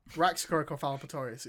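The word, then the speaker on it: Raxacoricofallapatorius: Raxacoricofalopatorius, it's really easy.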